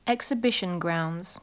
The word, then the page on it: exhibition grounds: English